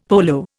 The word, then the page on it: polo: Indian